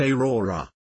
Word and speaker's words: Aura: When I was around 20, a few years ago, I kept having dreams about a woman with long black hair named Aura, pronounced Aurora.